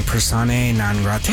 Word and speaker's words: Persona Non Grata: Persona Non Grata.